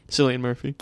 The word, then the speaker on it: Cillian Murphy: Cillian Murphy.